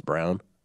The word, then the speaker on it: Braun: Apparently, it's not pronounced Braun now; it's Brown.